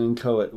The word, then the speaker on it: inchoate: It does, but in an inchoate way. – Yeah, well, I like the use of the word inchoate, but Inchoate? – I believe they're both acceptable pronunciations.